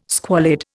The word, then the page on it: squalid: Indian